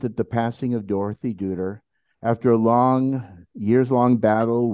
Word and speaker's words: Duder: One of the low points was the passing of his mate, his muse, the love of his life, his wonderful wife, Dorothy Dudler. Am I pronouncing the last name correct? – Deuter. It's like the Big Lebowski.